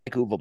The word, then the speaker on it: Uwe: Yes, Uwe Boll. Yeah. Nailed it, brother. Look at you, TV. It's Uwe Boll because I like him. I actually do like Uwe Boll.